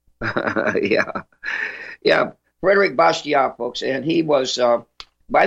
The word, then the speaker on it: Bastiat: Oh, I am appalled by your pronunciation, Monsieur Kelso, and I would like to quote Frédéric Bastiat. – Uh-oh. Yes, that's Bastiat. Frédéric Bastiat.